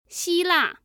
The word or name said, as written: xila